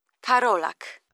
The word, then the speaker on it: Karolak: It's Agata Karolak. – Agata? – Karolak. – How do you spell that? – It's K-A-R-O-L-A-K. – L-A-K.